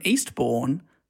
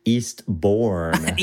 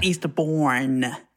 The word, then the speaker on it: Eastbourne: This person's from Eastbourne. They're simply known as Tom. – I think, I'll hold you up there. I think it's pronounced Eastbourne. – Eastbourne.